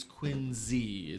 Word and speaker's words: Quincy: And Quincy is Quincy, is how you say it.